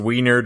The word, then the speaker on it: Weiner: Wasn't it also pronounced Wiener, depending upon who you talked to? – I didn't want to try and pronounce that. I didn't want to give you ammunition. – Sorry. Yes, I have actually heard it pronounced that way, so we're going to go with Wiener.